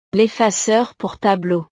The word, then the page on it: l'effaceur pour tableau: frances